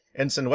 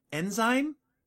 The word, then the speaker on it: ensign: How did you mispronounce it? – Enzyme? – No, that's a different.